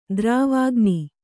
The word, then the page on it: ದ್ರವಾಗ್ನಿ: dravāgni